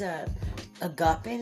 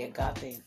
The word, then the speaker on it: agape: And agape is A-G-A-P-E. And it looks like grape, but without the R. Agape, it's just agape, but it's not spelled agape. It's pronounced, it's, their A is pronounced as a long or short O, agape. So, but it's spelled with an E.